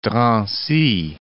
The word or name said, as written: Drancy